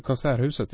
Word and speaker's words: Konserthuset: The way they've designed the, I think you pronounce it, the Konserthuset? – Konserthuset, yes.